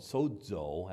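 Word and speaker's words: sozo (σῴζω): It was the word that said, it's the Greek word called sozo, that's how you pronounce it, but it means salvation.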